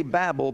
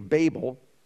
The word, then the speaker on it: Babel: Now, we say Babel, but the proper pronunciation is Babel, which the children of men builded.